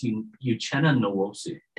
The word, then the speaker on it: Uchenna Nwosu: How does him, Shelby Harris, and I can never say his name correctly. – No, you're good. It's Uchenna Nwosu.